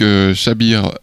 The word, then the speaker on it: Xabier: On écoute donc Xabir Badiola, ça s'écrit X-A-B-I-E-R, son prénom, si vous voulez le réécouter sur les internets.